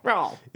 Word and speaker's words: Creole: Like, you're not supposed to fully pronounce Creole, you know? Like, there's... Yeah. – Creole. – Crawl.